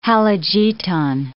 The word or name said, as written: halogeton